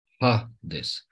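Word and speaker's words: Hades (ᾍδης): Dar când avem pe Hades, îl avem așa, Hades. Chiar dacă apare și iota aici, dar nu-l mai pronunțăm.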